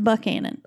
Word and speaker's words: Buchanan: Yes, but in Georgia, it's pronounced Buckhannon.